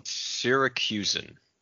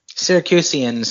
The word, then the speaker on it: Syracusan: So Syracusan. You were close. You added one extra syllable, I think. – Syracusian sounds better than Syracuse.